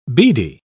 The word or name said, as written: beady